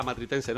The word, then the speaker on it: Matritense: más conocida como la Matritense. No sé si lo he dicho bien, Manu. – Perfectamente.